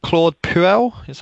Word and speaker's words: Claude Puel: Is it Claude Puel? Am I saying that right? – Yeah, that's right.